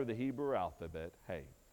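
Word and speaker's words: hey (ה): It looks like he, but I believe it's pronounced hey.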